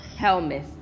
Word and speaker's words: helminth: I'm pronouncing the last word wrong. Intestinal helminth. – That's what it is.